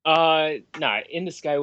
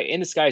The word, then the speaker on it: In the Sky: In the Sky was an ace pronunciation. Sorry that my Skype account is under like Ocean View, but yeah, In the Sky is totally fine.